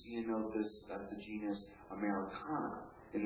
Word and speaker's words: Ceanothus: Ceanothus is the genus, and that's spelled C-E-A-N-O-T-H-U-S, Ceanothus.